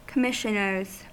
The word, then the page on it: commissioners: American English